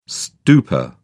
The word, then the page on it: stupa: British English